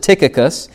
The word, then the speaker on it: Tychicus: Paul mentions a man by the name of Tychicus or Tychicus. I've even heard people pronounce it Tychicus.